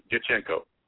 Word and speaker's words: Diachenko: It's like an Italian name. Think about like a D apostrophe, Diachenko. So it's Diachenko.